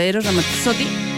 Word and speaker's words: Eros Ramazzotti: Bueno, vamos a encontrar la de Eros Ramazzotti. ¿Cómo se pronuncia? Eros Ramazzotti.